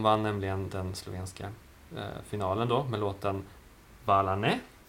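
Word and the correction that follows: Hvala, ne: om man uttalar rätt. – Kvalane. – Kvalane.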